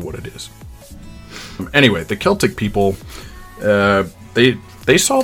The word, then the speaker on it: Celtic: Um, and before I get a lot of angry emails about my pronunciation about the Celtic people, hear me when I say that both the Irish and Welsh use the letter C to indicate the sound represented by the letter K in English.